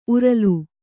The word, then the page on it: ಉರಲು: uralu